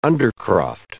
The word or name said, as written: undercroft